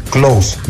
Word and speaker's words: Klose: Se pronuncia así. Klose, Miroslav Klose.